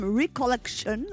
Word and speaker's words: Recollection: Notre premier invité sera Tom Leb, qui vient de sortir son premier album « Recollection ». – On m'a dit de le prononcer en anglais.